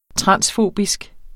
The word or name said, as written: transfobisk